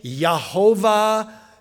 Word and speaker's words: Yahweh: So Yahweh, Yahweh, Yehovah, you know, we say Jehovah, but they wouldn't have had a J sound, you know, pronounced like that.